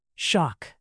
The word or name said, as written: shock